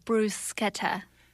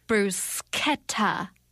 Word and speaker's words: bruschetta: Out of doubt, bruschetta is how the Italians say it. Though, of course, there are many people who say bruschetta.